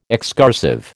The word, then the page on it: excursive: American English